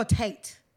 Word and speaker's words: Tate: Is it Tay Tay? – I think it's... Oh, Tate. T-A-T. – Okay, I thought I was saying it wrong. – Tate.